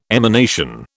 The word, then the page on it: emanation: British English